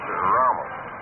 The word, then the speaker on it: Ramos: Ramos, proper pronunciation is Ramos.